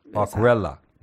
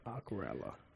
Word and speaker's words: Aquarela: Aquarela. That's how you pronounce it, by the way. – Aquarela.